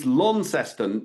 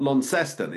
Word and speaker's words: Launceston: Now, I was told when I was in Launceston that you've got to hit it's Launceston, not Launceston. Is that correct? – Lawn, yeah, got to say lawn. There's no lawn there, it's Launceston. – Yeah, there's no lawn. – Launceston. Get that one right.